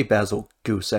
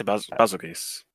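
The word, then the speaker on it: Bazelgeuse: How do you say Basil Goose, actually? Do you guys say something different? – I say Basil Geese. – I say Basil Geese. – Basil Geese. Basil Geese. – Everyone says it differently, basically.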